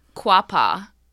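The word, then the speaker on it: Quapaw: So it's Quapa from the last episode for the Joplin Spook Light. Quapa. Quapa.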